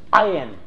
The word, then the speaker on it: ayin: In fact, properly in the Hebrew or Arabic, it sounds something like ah, down in your throat. So the letter actually is properly pronounced close to ayin.